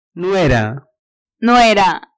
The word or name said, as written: nuera